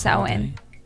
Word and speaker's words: Samhain: Also, it's pronounced Samhain. – Okay, Samhain? – Yeah.